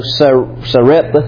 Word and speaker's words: Sarepta: How do you pronounce that? Sarepta.